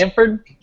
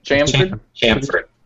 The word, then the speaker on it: chamfered: And chaffered. I wasn't even paying attention when you pronounced it. I was like, I wonder how you pronounce that word. – Is that what you said? – I think it's chamfered. – Chamfered. – Pretty sure it's chamfered.